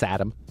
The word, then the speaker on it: Saddam: It's pronounced Saddam. – Saddam Hussein. Yeah, Saddam. That's right.